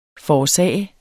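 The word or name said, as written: FORÅRSAGET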